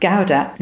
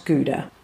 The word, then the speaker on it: Gouda: Try this half a white Brötchen with butter and a slice of young Gouda cheese, and it's pronounced Gouda, not Gouda topped with a slice of buttered Schwarzbrot.